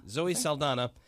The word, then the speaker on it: Zoe Saldana: Zoe Saldana.